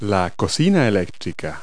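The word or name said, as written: electric stove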